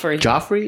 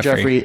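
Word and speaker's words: Geoffrey: I always fuck up when they spell it the G-E-O. – Joffrey. – Is it Joffrey? Is it sometimes. – Jeffrey? – But I think his Joffrey is Jeffrey.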